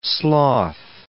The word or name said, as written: sloth